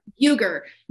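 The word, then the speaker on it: Uygur: Cenk, was how you spell, how do you say his last name? Uger? – Uger.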